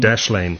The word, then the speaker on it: Dashlane: It's called Dashlane, Dashlane, and not as I sometimes pronounce it, Dashlane, it's a South African, Dashlane.